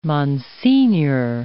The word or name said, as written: monsignor